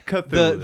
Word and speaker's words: Cthulhu: Yeah, Cthulhu.